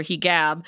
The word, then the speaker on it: hijab: We should point out, too, Fatima mentions her hijab. Hijab is apparently the correct pronunciation in Egyptian Arabic, so.